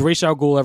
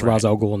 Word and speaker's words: Ra's al Ghul: I use whatever pronunciation they use in the show or movie. I go by theirs. – Yeah. – So it's Ra's al Ghul everywhere else, and it's Ra's al Ghul in that movie.